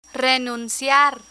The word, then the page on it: renunciar: Spanish